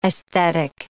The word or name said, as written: aesthetic